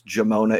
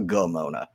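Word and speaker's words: Gimona: Guys, it's Italian. It's a soft sound. It's Jimona. It's not Gomona.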